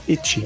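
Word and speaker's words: ecchi: H em japonês você pronuncia eti.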